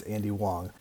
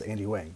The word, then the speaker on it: Wang: I'll put links to his stuff below, but first off, I've been pronouncing his name all along the wrong way. It's Andy Wong. It's spelled W-A-N-G, so I always thought it was Andy Wang, but it's Andy Wong, so that way, that's first off, to get that out of the way. Now you know the correct pronunciation of his name.